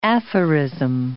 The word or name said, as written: aphorism